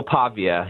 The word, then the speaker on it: Pavia: And tell me about your concerns going into Vanderbilt to take on, is it Diego Pava? Is that how you say his name? – Diego Pavia, yeah.